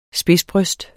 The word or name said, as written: spidsbryst